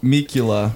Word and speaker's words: Mikula: M-I-K-U-L-A. – I also thought you were Samakula. I thought it was Mikula. It's like you say Kula, Mikula, because I'm cool, but it's spelled M-I-K-U-L-A. A-A-A-A.